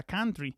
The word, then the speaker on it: country: está corrigiendo la pronunciación de country. Varias veces lo ha dicho muy bien, o sea, country, abriendo esa A, y por ello le felicito, pues sé que es muy difícil quitarse costumbres arraigadas.